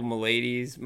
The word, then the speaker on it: maladies: How do I say it for magical maladies? – Maladies. Magical maladies. – Magical maladies. Gotcha. Good stuff. Yeah. All my ladies. No. Magical melodies. Maladies. Magical maladies. Yeah.